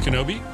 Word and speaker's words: KNBI: It's KNBI, pronounced Kenobi.